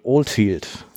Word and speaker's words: Oldfield: Ist das eigentlich richtig ausgesprochen? Oldfield? – Ja, englischer Name, so wie der Spogress-Name eigentlich auch englisch ist. So ein okay. bisschen. – Äh, nee, aber war, war richtig ausgesprochen, genau.